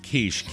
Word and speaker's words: quiche: And I got to tell you, in a little inside baseball here, Jackie Orchard writes the script, and she phonetically spelled quiche, K-E-E-S-H, for me, because she knows I'm a Luddite.